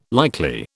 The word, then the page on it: likely: British English